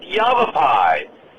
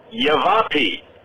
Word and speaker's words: Yavapai: But you didn't pronounce it Yavapai. You pronounced it Yavapi.